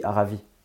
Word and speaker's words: Aravis: Alors attention, on ne prononce pas le S final. – Hmm. Tu dis Aravis.